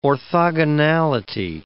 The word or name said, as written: orthogonality